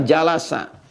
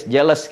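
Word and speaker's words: Jalasa (جلس): Majlis ini asalnya dari kata jalasa. Jalasa. Jalasa. Bacanya pakai bahasa Arab ya, jangan pakai bahasa Inggris. Jealous gitu. Jalasa, ya.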